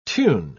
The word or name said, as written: tune